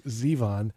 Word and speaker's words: Zevon: I just found out that you actually pronounce his name Zevon.